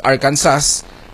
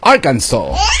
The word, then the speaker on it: Arkansas: Just like other states na iba yung spelling, iba yung pronunciation, like Arkansas, pronounced as Arkansas.